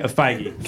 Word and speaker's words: Feige: Yeah, Feige.